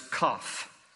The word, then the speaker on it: kaph: Verse 81, the Hebrew letter is kaf, K-A-P-H. Sounds like, you know, when someone is sick and they cough.